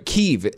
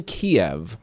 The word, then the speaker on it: Kyiv: And someone else said Kyiv, right? Kyiv. It's Kyiv everywhere since the start of the war over in Ukraine. It's been Kiev in our pronunciation for forever. I would just, you know, and then all of a sudden, everyone starts pronouncing it the right way.